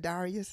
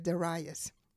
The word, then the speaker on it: Darius: Some pronounce it Darius, some pronounce it Darius. I'm going to pronounce it Darius.